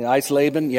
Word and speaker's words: Eisleben: Is that right? Okay, Eisleben, yeah.